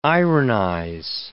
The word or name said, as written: ironize